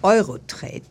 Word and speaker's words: Eurotrade: Ja, ihr kommt ja von der Firma Eurotrade. Ist es richtig ausgesprochen? – Ja, das ist die Eurotrade Handels GmbH.